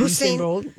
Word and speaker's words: Usain Bolt: Usain Bolt, yeah.